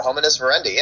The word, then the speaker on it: Hominis Verendi: Like, it's Latin, you just pronounce every letter. Hominis Verendi.